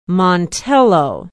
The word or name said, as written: montello